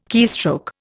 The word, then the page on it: keystroke: Indian